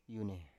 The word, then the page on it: yune: Biak